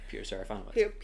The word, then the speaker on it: Peter Serafinowicz: Peter Serafinowicz.